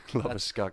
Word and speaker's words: Labuschagne: Labuschagne. – That's the South African pronunciation, I believe.